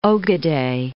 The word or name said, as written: ogodei